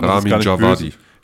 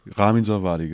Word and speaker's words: Ramin Djawadi: Ramin Djawadi. – Ramin Djawadi, genau.